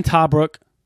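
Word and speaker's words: Tebrake: LSU signed Dylan Tabrook. That's how you pronounce his name.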